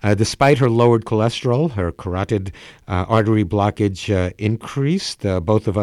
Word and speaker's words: carotid: And when she came to see me for carotid, is that the pronunciation? Carotid, the carotid arteries. I think the dictionary said that was the pronunciation.